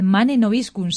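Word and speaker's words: Mane nobiscum: Sed bienvenidos a este espacio y nosotros vamos a empezar preguntando, que seguramente nuestros oyentes pregunten que qué es esto de Manen Obiscun, si no lo pronuncio mal, Gonzalo, si no, corrígeme. – Está bien pronunciado.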